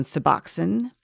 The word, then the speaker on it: Suboxone: Suboxone.